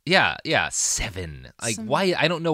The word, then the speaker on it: Sevin: pesticide called Seven, and it's spelled S-E-V-I-N.